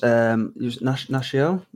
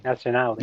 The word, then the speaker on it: Nacional: So like Fernando is national, was it? – Nacional.